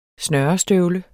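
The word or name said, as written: SNØRESTØVLE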